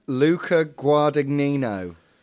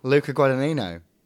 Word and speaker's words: Luca Guadagnino: Luca Guadagnino. Guadagnino? – Bless you. – Luca Guadagnino.